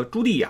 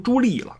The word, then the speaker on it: 朱棣: 然后上期还犯了个错误，把那个朱棣呀念成朱棣了，这是充分暴露了对中国文化、历史文化的一个不了解，就是没怎么看中国的这边的历史，或者说看的时候也没有怎么求甚解。